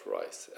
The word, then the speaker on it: Rhys: Jag tror det uttalas Rice, R-H-Y-S.